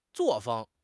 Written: 作风

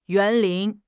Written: 园林

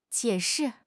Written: jie3 shi4

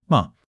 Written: me5

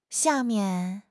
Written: xia4 mian4

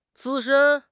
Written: 资深

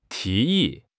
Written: 提议

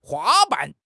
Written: 花瓣

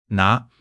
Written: 哪

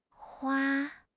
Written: hua1

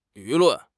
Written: 舆论

